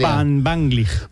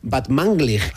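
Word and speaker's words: Batmanglij: Van Vanglig. – Batmanglig.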